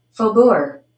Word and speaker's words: Faubourg: Let's see what the internet says, how you're supposed to pronounce this. – Faubourg. Faubourg. – Faubourg. – Faubourg. – Faubourg. Oh, okay. So maybe that's how you're supposed to pronounce it.